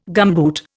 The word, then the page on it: gumboot: Indian